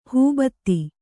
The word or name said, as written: ಹೂಬತ್ತಿ